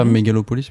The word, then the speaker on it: Megalopolis: Megapolis, ça avait été négocié avec le syndicat des acteurs scénaristes. – C'est pas Megalopolis plutôt ? – Megalopolis. – Ouais, je crois. – Il me manquait une syllabe, merci.